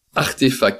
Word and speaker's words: RTFKT: Est-ce que vous connaissez euh, Artefact qui se lit, qui s'écrit RTFKT Non non, moi je sais pas.